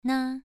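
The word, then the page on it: na: tone 2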